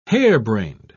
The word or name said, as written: harebrained